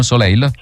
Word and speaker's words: Soleil: Ho detto bene questo, Soleil? – Sì, sono ingarrato.